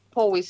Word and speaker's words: Powis: It's um 34 to 35 Powis Square.